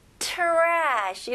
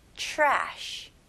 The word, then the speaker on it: trash: 발음은요, 여기서 TR로 시작하지만 여기서는 CHR 이렇게 발음하시면 훨씬 더 발음하기 쉬울 거예요. 그래서 trash 이렇게 하는 것보다는 CH 발음으로 시작해서 trash, trash, very good. 이름절로 하셔야 됩니다. trashy가 아니고요, trash. Okay.